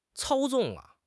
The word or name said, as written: 操纵